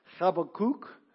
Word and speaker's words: Habakkuk: I think the proper pronunciation is Habakkuk. But you'll never hear me saying it that way. You'll probably hear me saying it all different ways. Habakkuk.